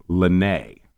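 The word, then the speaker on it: Le Nez: Then the spelling is kind of interesting. It's L-E-N-E-Z, but it's actually pronounced Lenay. – Right. It's the French for the nose, yes.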